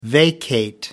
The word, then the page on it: vacate: American English